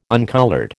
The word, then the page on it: uncoloured: American English